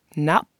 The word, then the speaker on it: nafn: The Icelandic word for today is appropriately name, which is pronounced nap, nap. And if you look on my website at this word, that in English, the letters would be N-A-F-N, the F sounds like a P, and then they have this kind of breathing out through the nose sound that's happening at the end.